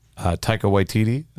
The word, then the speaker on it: Taika Waititi: Taika Waititi, I believe is how his name is. So I got his name right.